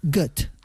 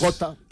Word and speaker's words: Goethe: Y según he visto, se pronunciaría Goethe. – Mira, bueno, como pues, Gota.